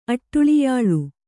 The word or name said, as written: ಅಟ್ಟುಳಿಯಾಳು